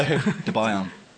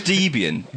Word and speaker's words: Debian: Dubaiyam. – What? – Everyone just pronounces Debian in a different way. – Dubaiyam. – Dubaiyam. – It's Debian. Debian. Dubaiyam. – But it's Debian.